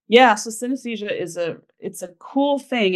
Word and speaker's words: synesthesia: Yeah, synesthesia.